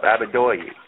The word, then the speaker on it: Abidoye: Abidoye, right? – Yeah, absolutely.